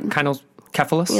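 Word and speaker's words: kynokephalos: It's like kinocephalios, but kinocephalos.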